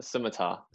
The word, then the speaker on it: scimitar: A scimitar.